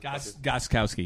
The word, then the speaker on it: Gostkowski: Between a rookie kicker in the Super Bowl and Dostowski or whatever the Gostkowski. Use your words. – There it is. Yeah. – Just pronounce every letter in his name in order. You'll get it.